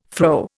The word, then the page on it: fro: Indian